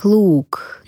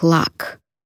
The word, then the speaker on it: Лак: На кантонском наша фамилия звучит как Лук, но британцы предпочитают произносить ее как Лак.